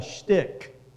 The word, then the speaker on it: stich: Each of those lines is called a shtick, rhymes with stick, S-T-I-C-H.